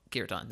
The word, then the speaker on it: kirtan: So first of all, kirtan. That's how you say it.